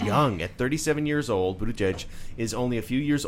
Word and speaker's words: Buttigieg: And if you want to learn to say his name, it's Buttigieg. And then say it fast. Buttigieg.